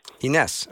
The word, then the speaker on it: Inés: Ines, welcome. – Thank you. Thank you for having me. – Yeah. I hope I'm saying your name right, am I? – Yes, Ines. – Ines.